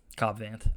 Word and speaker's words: Cobb Vanth: Cobb Vanth.